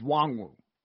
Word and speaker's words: Nwangwu: And the running back from Iowa State, Kenne Nwangwu, correct? Is that how you pronounce it? – The N is not said, so it's just Wangwu.